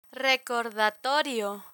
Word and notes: recordatorio: Spanish

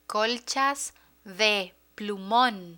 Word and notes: colchas de plumon: spanish

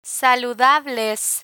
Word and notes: saludables: spanish

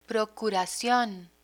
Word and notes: procuracion: Spanish

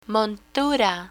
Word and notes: montura: Spanish

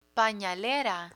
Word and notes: panalera: Spanish

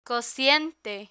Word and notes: cociente: spanish

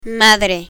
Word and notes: madre: spanish

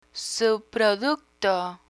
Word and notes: subproducto: Spanish

